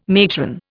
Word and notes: matron: Indian

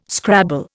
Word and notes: scrabble: Indian

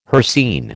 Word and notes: hircine: American English

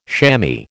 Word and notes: chamois: American English

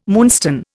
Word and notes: moonstone: Indian

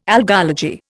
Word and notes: algology: Indian

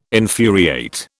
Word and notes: infuriate: British English

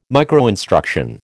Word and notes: microinstruction: American English

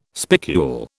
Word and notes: spicule: British English

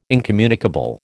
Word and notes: incommunicable: American English

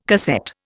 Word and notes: cassette: Indian

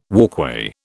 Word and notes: walkway: British English

